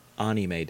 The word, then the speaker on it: anime: Amine. – It's pronounced anime, Dad.